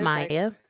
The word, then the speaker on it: Mya: Is Maya. – How do you spell that? – M-Y-A. – M-Y-A.